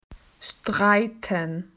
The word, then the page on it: argument: Australian English